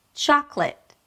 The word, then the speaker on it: chocolate: Now, as you can hear, there are only two syllables in this word, the way I'm pronouncing it. – Chocolate. – You start with a ch sound and then you open it to the a as in father.